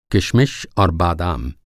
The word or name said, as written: किशमिश